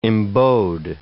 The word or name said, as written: embowed